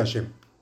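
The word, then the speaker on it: Hashem (השם): Deberá meditar el santo nombre de Hashem, que se escribe Hei Shin Mem, se pronuncia Hashem.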